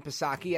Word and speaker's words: Psaki: Stephen Portnoy, CBS News, asked a very legitimate question to Jen Psaki at the White House. And that's Silent P, of course. Jen circled back Psaki, saying, with regard to the eviction moratorium, is Biden signaling that he doesn't respect the rule of law?